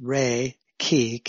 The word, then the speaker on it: Reiki: It's pronounced Reiki, K-E-E.